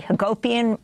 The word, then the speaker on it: Hagopian: Jesse Hagopian rhymes with utopian.